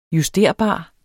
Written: justerbar

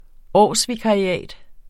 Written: årsvikariat

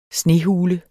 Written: snehule

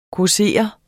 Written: causere

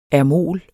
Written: ermol

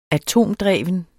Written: atomdreven

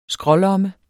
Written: skrålomme

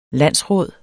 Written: landsråd

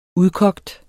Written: udkogt

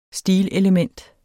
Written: STILELEMENT

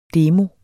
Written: DEMOER

